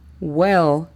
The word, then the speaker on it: well: La pronunciación fonética de all right sería O-L, R-A-I-T, all right. La siguiente frase o marcador es well. Well significa bueno. La pronunciación fonética de este sería U-E-L, well.